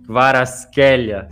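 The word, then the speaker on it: Kvaratskhelia: Aí, felizmente, ele gravou um vídeo lá que ele escreve o nome dele na língua dele e depois a forma como se fala, é o famoso Varaskelia, segundo ele mesmo.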